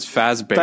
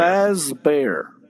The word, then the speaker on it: Fazbear: It's Fazbear. – Fazbear.